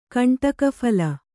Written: ಕಂಟಕಫಲ

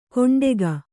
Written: ಕೊಂಡೆಗ

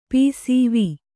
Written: ಪಸಿವು

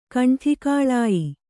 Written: ಕಂಠಿಕಾಳಾಯಿ

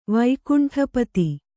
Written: ವೈಕುಂಠಪತಿ

